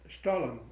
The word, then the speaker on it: Stollen: That Stollen.